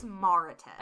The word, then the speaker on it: Mauritius: I just have never really known how to say it. It's Mauritius.